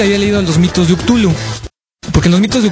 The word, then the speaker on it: Cthulhu: Aunque yo de metiche me encontré por ahí un libro de Derleth, una carta de Lovecraft en la que debaten el punto y dicen que la traducción correcta es Cthulhu. Yo lo voy a seguir pronunciando con Cthulhu.